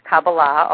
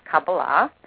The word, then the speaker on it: Kabbalah: And then in my 30s, I happened to learn a little something about Jewish mysticism, or Kabbalah, which is correctly pronounced Kabbalah, although nobody ever pronounces it that way. Even most Jews don't pronounce it that way, but that's the correct pronunciation. It is Kabbalah.